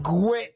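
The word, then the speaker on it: Guè: Martini con Marrakesh featuring Gue, che c'ha l'accento sulla E.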